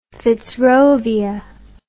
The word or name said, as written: fitzrovia